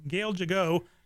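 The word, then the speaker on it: Gal Gadot: She said jig like a jig, and then I got the accompanying jigging, getting jiggy, and oh. – Okay. – So there we go. So Gail Gigot, and we're going to post her written name on our website later so you know who the heck we're talking about.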